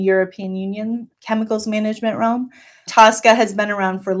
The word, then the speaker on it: TSCA: So the Toxic Substance Control Act, which is often, the acronym is often pronounced TSCA, is the premier chemicals management regulation in the United States.